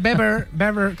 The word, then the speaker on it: Weber: Está Manfred Weber, que se dice sí, Weber, sí. No se dice Weber, ¿vale? – Sí, sí. – Se dice Manfred Weber.